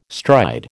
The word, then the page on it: stride: American English